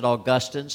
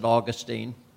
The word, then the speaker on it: Augustine: There was a church father, a brilliant theologian long ago by the name of, some pronounce it Augustine, some pronounce it Augustine.